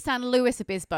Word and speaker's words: San Luis Obispo: It's San Luis Obispo, and I wasn't going to correct you, because we can say, actually, Amber Marie, this is for you.